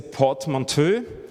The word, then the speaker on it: portmanteau: Der erste Satz ist, it's a portmanteau. Oder Portmanteau, wie sie es aussprechen auf Englisch, aus Federation und Universe.